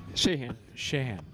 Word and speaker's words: Shahan: I think you shoved in an extra syllable. – You added a couple syllables. – Oh. – You're good. I'm just messing with you. – Shit. – Shahan.